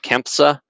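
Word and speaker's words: CANFSA: Bit of a mouthful, but CAMPSA is how you say it, that acronym, which was not exclusively aerospace focused, but it had heavy aerospace involvement from some of the membership.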